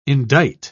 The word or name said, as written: indict